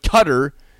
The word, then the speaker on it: Qatar: But cutter, C U T T E R for Q for Q A T A R is how you pronounce it. Cutter.